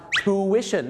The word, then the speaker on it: tuition: โทษนะครับเขาออกเสียงว่า tuition นั่นเองครับ tuition นั่นเองครับ tuition นะครับไม่ใช่ tuition หรือ tuition อะไรงั้นไม่ใช่นะครับผม